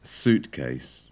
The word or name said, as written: suitcase